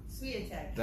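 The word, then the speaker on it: Świątek: You might be able to pronounce her name, but Inga Swiatek, something like that. What? – Swiatek. – That's the one.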